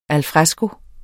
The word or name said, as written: al